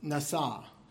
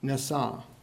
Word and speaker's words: nasa: And in Hebrew, it's pronounced nasa, nasa. And it means to lift up or to carry. And as I was learning Hebrew for my seminary classes, I remembered this word because nasa sounds like NASA, right?